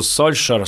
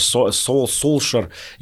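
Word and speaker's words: Solskjær: Есть знаменитый пример про нынешнего тренера Манчестер Юнайтед, который пишется по-норвежски «Сольскьяер» с лягушкой, а произносится он что-то между «Сольшер», «Солшер». Я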